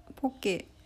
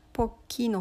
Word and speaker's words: poke: そのポケはハワイ語寄りの発音ちょっとローマ字みたいな感じなんですけどポッキーの方はアメリカ英語から来ているみたいです